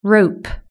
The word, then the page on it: rope: British English